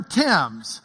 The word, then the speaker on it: Thames: It's just T-I-M-S, the River Thames.